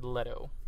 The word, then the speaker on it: Leto: Just like I found out that it's pronounced Jared Leto. I've been saying Leto.